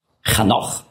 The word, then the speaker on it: Chanoch: Henoch, Chanoch auf Hebräisch ausgesprochen, heißt der Geweihte, der Hingegebene.